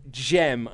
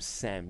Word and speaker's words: Cem: I'll definitely be emailing in, so for future reference, my name is read Jem. I keep calling him Sam, because it's spelled C-E-M.